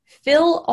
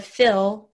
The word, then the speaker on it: feel: So if I say feel, that's, I mean, I feel like I said it right then, F-E-E-L, instead of fill, like F-I-L-L.